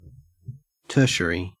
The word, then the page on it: tertiary: Australian English